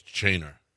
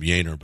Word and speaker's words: Yainer: It's Y-A-I, but it's Chainer. – Really? – Yeah. Yeah, for whatever reason. I like to call him Yainer, but but other people, announcers, call him Chainer for whatever reason.